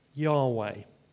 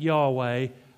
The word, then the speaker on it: Yahweh: And it may have been pronounced something like Yahweh.